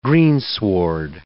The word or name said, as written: greensward